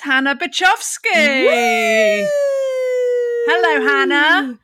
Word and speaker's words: Byczkowski: Hannah Byczkowski.